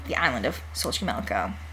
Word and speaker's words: Xochimilco: I cheated and looked up the pronunciation. – Oh. – Because I didn't... It's like, it's got X's and H's and... – The little ticks over the E's. – Xochimilco.